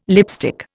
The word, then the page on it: lipstick: Indian